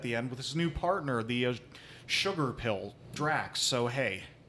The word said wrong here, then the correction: Drix: Is it Drax or Drix? – Drix. – Drix. – Saying it wrong probably the entire time.